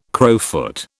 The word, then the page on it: crowfoot: British English